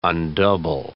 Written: undouble

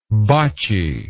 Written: botchiness

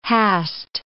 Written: hast

